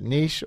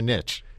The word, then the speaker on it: niche: Your caller is correct in that niche is the pronunciation that's historically been used in English. Obviously, it's a word from French, but it was nativized and into an English pronunciation as niche for a long time.